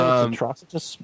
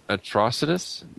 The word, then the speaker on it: Atrocitus: Basically, Guy Gardner and Ganthet show up to Atrocious? Is that how you say his name? I have no idea. – I think I've said Atrocitus, but... – Atrocitus? Okay. – Atrocitus sounds good.